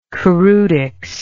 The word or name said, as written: choreutics